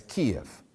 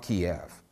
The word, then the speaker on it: Kyiv: You will have noticed in the dispatches coming out of Ukraine that more and more media outlets now refer to the country's capital as Kyiv, not Kiev. They're opting for the Ukrainian pronunciation over the Russian one.